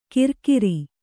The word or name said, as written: ಕಿರ್ಕಿರಿ